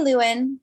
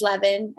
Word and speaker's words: Lewin: So I say Lewin. However, you know, if you're German, it is Lewin, and I do have German heritage, so either one is fine.